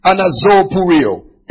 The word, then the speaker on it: anazopureo: Anazopureo. A-N-A-Z-O-P-U-R-E-O. A-N-A-Z-O-P-U-R-E-O.